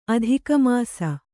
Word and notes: ಅಧಿಕಮಾಸ: adhikamāsa

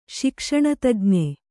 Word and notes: ಶಿಕ್ಷಣತಜ್ಞ: śikṣaṇa tajña

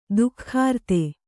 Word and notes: ಧಿಕೃತಿ: dhikřti

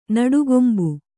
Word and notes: ನಟ್ಟಗೊಂಬು: naṭṭagombu

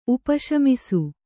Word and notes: ಉಪಶಮಿಸು: upa śamisu

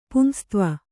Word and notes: ಪುಂಸತ್ವ: pumsatva

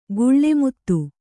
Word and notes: ಗುಳ್ಳೆಮುತ್ತು: guḷḷe muttu

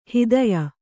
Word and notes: ಹೆತ್ತಯ್ಯ: hettayya

